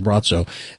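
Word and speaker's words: Braco: Brazco, but it's pronounced Brazzo, the Croatian Gazer.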